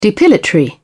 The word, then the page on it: depilatory: British English